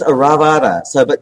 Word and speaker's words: Oravida: Yeah, I think it's Aravada. O-R-A-V-I-D-A. But I like that we'll call it the rubber ducky because it sounds like a duck.